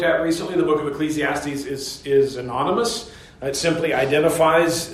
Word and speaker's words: Ecclesiastes: And so the English title then, Ecclesiastes, is just an Anglicanized pronunciation of that Greek title, translating the Hebrew word meaning assembly, or one who gathers together an assembly.